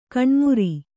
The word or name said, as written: ಗೋಣುಮುರಿ